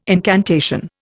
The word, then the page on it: incantation: Indian